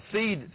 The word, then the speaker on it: Cede: It's called Seed and Company. C-E-D-E, and it's spelled in all capital letters like that.